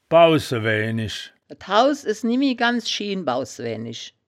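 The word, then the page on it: bausse-wäänisch: wittlicher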